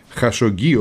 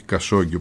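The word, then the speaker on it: Khashoggi: του Χασογγί, όπως πρέπει να προφέρεται καλύτερα στα αραβικά και όχι Κασόγγι όπως γράφουμε εμείς εδώ στα ελληνικά και ευρωπαϊκά μέσα.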